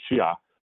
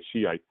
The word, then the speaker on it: Shia: The proper way to say it is Shia, but commonly in the West, we say Shiite.